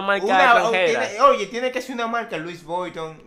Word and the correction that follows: Louis Vuitton: Louis Vuitton.